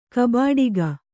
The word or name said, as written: ಕಬಾಡಿಗ